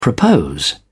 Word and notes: propose: British English

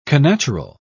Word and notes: connatural: British English

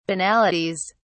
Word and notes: banalities: British English

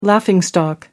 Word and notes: laughing stock: American English

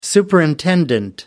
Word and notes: superimpose: American English

